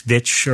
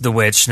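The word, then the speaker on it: The VVitch: do you care about people online arguing about if it's The Witch or the two V's, Vitch? Or? – Well, I mean, it's always pronounced The Witch, no matter how you spell it.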